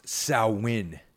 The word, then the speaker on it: Samhain: And as I mentioned before, the name Samhain is pronounced Samhain to those of you out there who are familiar with Wicca or witchcraft.